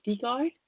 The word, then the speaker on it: Stigaard: Well, I do classes all over the world all the time, and you can find me on Facebook, Hanne Stigard, and my name is spelled H-A-N-N-E. Stigard is spelled S-T-I-G-A-A-R-D.